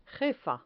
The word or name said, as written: jefa